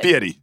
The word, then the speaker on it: Fieri: Fieri.